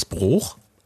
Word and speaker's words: Broich: Also bei uns hier in der Ecke wird ja ein UI oft als entweder Ü oder als Dehnungsvokal dann benutzt. Ne? Aber hier Kluim. – Was ist denn Dehnungsvokal? Dass das dann länger ausgesprochen wird oder was? Also, ja, im Schloss, hier zum Beispiel in Mülheim, Schloss Broich. Da gibt es auch Leute, die sagen Schloss Broch.